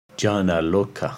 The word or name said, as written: Janaloka